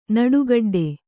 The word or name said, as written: ನಡುಗಡ್ಡೆ